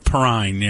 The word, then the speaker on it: Perrine: She was Perrine, wasn't it? – Perrine, yeah. I never knew how to say her last name.